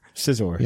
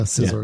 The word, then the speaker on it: Scizor: I pronounce it Scizor too. – That's how the little Pokedex application told you to pronounce it many, many years ago.